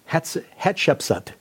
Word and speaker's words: Hatshepsut: one of the most prominent being Queen Hatshepsut. Is that correct pronunciation? – Mm-hmm.